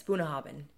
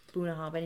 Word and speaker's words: Bunnahabhain: It can be tricky to pronounce, but again, Gaelic just kind of rushed the tricky parts. Boonahabin, you know, so that B and that H, because it's spelled B-U-N-N-A-H-A-B-H-A-I-N. Boonahabin, and you just rush it, it'll come out of your mouth perfectly, and it means foot of the river.